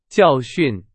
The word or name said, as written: 教训